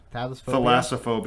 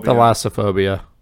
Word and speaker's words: Thalassophobia: Thalassophobia. – Thalassophobia.